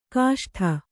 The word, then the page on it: ಗಸಟು: gasaṭu